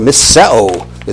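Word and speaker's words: misseo (μισέω): misseo is the way it's pronounced.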